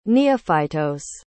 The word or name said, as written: neophytos